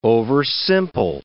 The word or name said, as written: oversimple